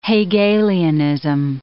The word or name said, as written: Hegelianism